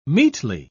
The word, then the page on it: meetly: British English